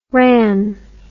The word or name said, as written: wran